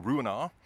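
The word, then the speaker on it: Ruinart: I'm privileged to work on some of the finest brands, as you can imagine, including Rouenard. You don't pronounce the T, and that is the oldest champagne house in the world.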